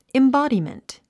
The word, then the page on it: embodiment: imbάdimənt; インボォディメント